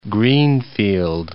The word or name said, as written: greenfield